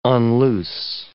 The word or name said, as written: unloose